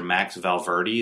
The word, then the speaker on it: Max Valverde: Today I have the privilege of interviewing Mr. Max Valverde.